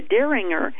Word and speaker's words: Dieringer: The Freedom of Conscience Legal Defense Fund is warning the Dieringer, that's spelled capital D-I-E-R-I-N-G-E-R, a little bit like John Derringer, isn't it?